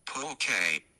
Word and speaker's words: pulque: Pulque.